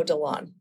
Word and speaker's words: DeLon: I married into that name, and my husband always says, pronounce it like Mo DeLon, like you're rowing.